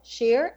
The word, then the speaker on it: Sher: Sher, S-H-E-R, who can be reached via her kind of like website.